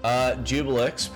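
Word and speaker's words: Juiblex: Jubilex, pronounced Jubilex,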